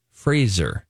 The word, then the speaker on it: Fraser: But Brendan Fraser wants everyone to know that his last name is pronounced Fraser, mm-hmm. – like razor, Rise of Lights. – Brendan Fraser. – So like laser, say Fraser.